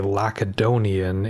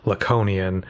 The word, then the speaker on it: Laconian: For example, in this episode, I say Lacedonian instead of the correct Laconian, several times.